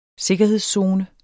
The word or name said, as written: sikkerhedszone